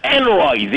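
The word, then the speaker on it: Henery: And, you know, but it doesn't work, because Peter Noon always said, I'm Enroy the Eighth. He added a syllable.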